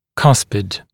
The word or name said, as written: cuspid